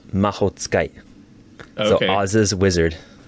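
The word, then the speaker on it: mahotsukai (魔法使い): And that word is mahotsukai. Mahotsukai. So it's like mahotsukai. Yeah, four syllables.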